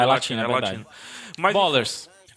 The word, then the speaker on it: Ballers: Estou vendo muita gente pronunciar como Bellers. Aí não, tá? Bellers? Bellers não pode. não, Bellers não. É, é simplesmente errado.